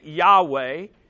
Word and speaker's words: Yahweh: The special name of God, the best that we know to pronounce it, Yahweh, meaning I am who I am, that's his name.